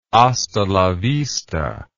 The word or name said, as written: hasta la vista